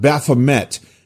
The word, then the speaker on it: Baphomet: Baphomet is, it's really not Baphomet because it's a French word. So it's Baphomet. I do believe that's the exact way to pronounce it.